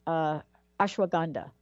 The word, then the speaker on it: ashwagandha: Let's start with ashwagandha, right? I don't even know if I'm pronouncing it right. – Yeah, you're good.